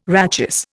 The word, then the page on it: rachis: Indian